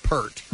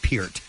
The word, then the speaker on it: Peart: And the name of the beer is called Pronounced Peart, Peart, because everybody calls him Neil Peart. – Right. – I did for ages until I heard the man himself say that it's pronounced Peart. So the name of the beer is called Pronounced Peart, P-I-E-R-T.